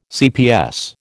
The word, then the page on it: cps: American English